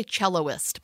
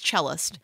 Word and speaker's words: cellist: Is that how you say it? Cellist? – Yeah. – I always want to say celloist, but it's cellist.